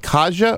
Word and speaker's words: Kaja: We have Kaja Robinson, and I told her that's a beautiful name. And it is. – It's spelled just like it sounds, Sue. K-A-J-A.